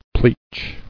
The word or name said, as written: Pleach